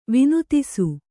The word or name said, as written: ವಿನೋದಿಸು